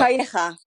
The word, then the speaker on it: Calleja: Weird story, because, you know, you had, your pronunciation of this will be much better than mine, but Javier Calleja, how's that? – Calleja. – Oh, there you go.